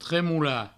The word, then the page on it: tremolar: tremoula